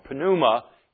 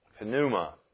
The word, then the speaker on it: pneuma: Pneumatos, or pneuma in the Greek. Sometimes that word is silent, but it's pneuma.